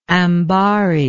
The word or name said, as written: ambary